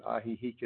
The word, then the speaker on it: Ajijic: And Ajijic is spelled A-J-I-J-I-C. It's the name of the town we're sitting in right now. It's not a Spanish word. It's a Nahuatl word, one of the ancient languages of Mexico.